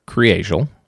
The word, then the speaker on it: Kriegel: So it looks like Kriegel. – Okay. – Like if you're just looking at it, K-R-I-E-G-E-L. But it's pronounced Kriegel.